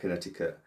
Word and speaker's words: Connecticut: Well, it's got that C in the middle of it. I didn't expect Connecticut. – Oh, yeah. Connecticut. – So it starts with the word connect. So, but it's pronounced Connecticut.